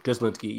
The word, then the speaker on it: Lynskey: Melanie uh, Lynskey, I think. – Lynskey, yeah, she's great in Yellow Jackets.